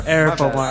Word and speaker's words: Arif Omari: Or Arif. Arif Omari.